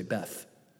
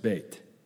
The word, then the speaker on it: Beit: Some people say Beth, but really it's pronounced Beit.